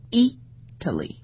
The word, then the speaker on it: Italy: 同样比如说像 Italy，然后那么它的重音是在 E 上面，那么这时候你会去读成 Italy。